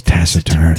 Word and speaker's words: taciturn: If you're going to do this, pronounce it right. It's taciturn.